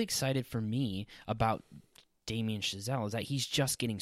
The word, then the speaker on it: Chazelle: I looked up the pronunciation on some French website. It's Chazelle, hopefully.